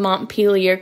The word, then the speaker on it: Montpelier: To the little things like pronouncing Montpelier correctly and not going around Montpelier. – Samantha's laughing because she learned from BLS that she'd been pronouncing our state's capital wrong. She thought it was Montpelier.